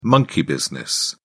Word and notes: monkey business: British English

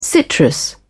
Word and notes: citrus: British English

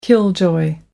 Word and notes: killjoy: British English

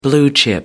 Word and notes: blue chip: American English

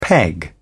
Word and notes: peg: British English

